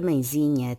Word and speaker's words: mãezinha: A palavra mãezinha tem acento tónico na sílaba zi.